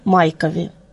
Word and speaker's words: Майков: 6 фактов об Аполлоне Майкове. Фамилия Майков произносится с ударением на первый слог.